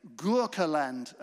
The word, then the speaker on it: Gurkhaland: Gurkhaland, yes, Gurkhas, okay? Gurkhaland, as they pronounced it.